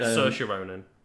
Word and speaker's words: Saoirse Ronan: Yes, Saoirse Ronan. – Is that how you pronounce that? I always said Saoirse Ronan. So it's Saoirse Ronan.